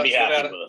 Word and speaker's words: Nevada: Let's talk about another state that I know, you know, apropos of our conversation about Nevada, I don't need to make the folks there correct me on my pronunciation. – You got to say it the right way. Right. – Yeah, I got it. I corrected myself. – Nevada. Nevada. Yeah, right? – Exactly.